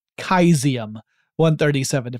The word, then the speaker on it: cesium: This particular device contained within it a pellet of cesium-137, or if you prefer, kaizium-137, if we're going to pronounce Caesar as Kaiser as we should.